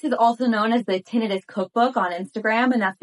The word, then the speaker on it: tinnitus: tinnitus. Tinnitus is also the correct pronunciation.